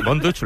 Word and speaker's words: Von Dutch: Así que, Von Dutch, ¿lo he pronunciado bien? – Sí, muy bien.